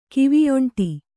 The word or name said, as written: ಕಿವಿಯೊಂಟಿ